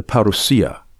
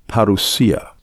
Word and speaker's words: parousia: I'll focus on one aspect of his return, the parousia. Now, I appreciate you've probably heard people pronounce this parousia, but you should put the emphasis on the right syllable. The pronunciation is more probably parousia.